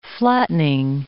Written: flattening

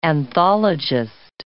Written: anthologist

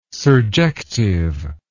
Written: surjective

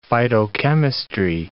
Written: phytochemistry

